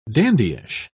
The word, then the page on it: dandyish: British English